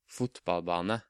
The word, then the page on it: fotballbane: Norwegian